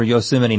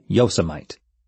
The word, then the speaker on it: Yosemite: Having no point of reference for Yosemite National Park, he pronounced it Yosemite.